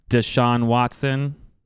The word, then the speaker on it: Deshaun Watson: Deshaun Watson.